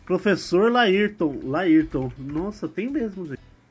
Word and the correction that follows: Layton: É Professor Layton. Isso, a pronúncia foi errada.